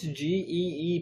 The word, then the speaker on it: Gee: How is G E E pronounced? – G E E is G. – G. – Like G Willikers.